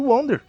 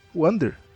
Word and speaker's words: Wander: Aquelas sombras pretas entram dentro do, do Wonder. Wander.